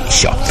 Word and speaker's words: Shaw: Bueno, aquí estamos, 605 Shaw, SHAW, 605, se pronuncia Shaw, y en hispánicamente hablando en español, Shaw, esa calle de las Cortes, de la Clínica Strawberry.